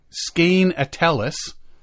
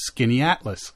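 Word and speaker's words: Skaneateles: If you look at it, we're looking at Skane Atellus, but it's actually pronounced Skinny Atlas.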